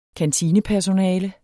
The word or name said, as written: kantinepersonale